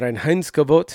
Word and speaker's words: Reinheitsgebot: You know, you don't roll your R's in German. The Reinheitsgebot, sorry.